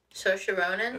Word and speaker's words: Saoirse: Saoirse Ronan? – That's it.